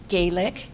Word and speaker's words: Galick: My name is Jeannie Galick, G-A-L-I-C-K.